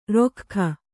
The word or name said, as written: ರೊಖ್ಖ